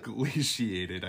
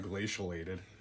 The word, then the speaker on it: glaciated: So anything that's not glacialated or glaciated, I think is how you say it, not glacialated.